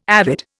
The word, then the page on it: avid: Indian